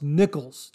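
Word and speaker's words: Niccolls: Now, his last name is spelled N-I-C-C-O-L-L-S. I believe that's pronounced Nichols. Jonathan, I hope I got that right.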